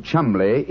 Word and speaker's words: Cholmondeley: Being an American, he had no reason to know that the name Chumley is in no way pronounced the way it is spelt. – But I never thought of that. Cholmondeley. That name's spelt C-H-O-L-M-O-N-D-E-D-E-L-E-Y.